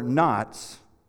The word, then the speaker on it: knots: It says, The Geek Squad, Geek Squad will untangle your knots with our tech no. Okay, you just have to see that in your head. You spell knots with a K. K is silent.